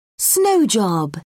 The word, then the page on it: snow job: British English